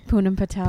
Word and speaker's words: Poonam Patel: Poonam Patel. – Poonam, yeah. – Patel. – Well, it's like, my parents say Poonam, but I don't.